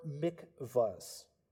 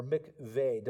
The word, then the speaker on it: mikveh: Every first century synagogue had one or more mikvahs, or mikveh, depending on how you pronounce it, and that is just the Hebrew word which means pooling of water.